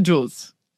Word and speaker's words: Jules: Jules.